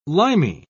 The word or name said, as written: limy